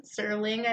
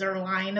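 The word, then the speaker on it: Zuerlein: Greg the Leg Zerling, I've been saying, and it's Zerline, apparently.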